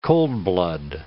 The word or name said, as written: coldblood